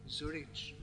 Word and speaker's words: Zurich: Zurich. I won't even try. It's the German z is different to the English one.